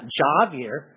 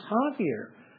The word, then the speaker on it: Javier: Javier, the J is spelled like an A, is pronounced like an H. Yes, J-A, it's not Javier, it is Javier.